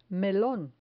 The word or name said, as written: melon